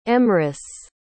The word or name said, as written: emrys